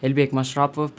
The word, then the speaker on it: Elbek Mashrapov: And it's right, but it's actually in Kyrgyzstan, like it sounds like this, Elbek Mashrapov.